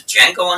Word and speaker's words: Django: which is Django Unchained. Came out 10 years ago. – The D is silent. – I did not say the Django. – I said the Django.